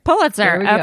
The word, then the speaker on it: Pulitzer: Pulitzer.